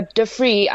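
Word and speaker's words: Dufry: It's a play on duty free. So Dufree, I think, is the right way to pronounce it.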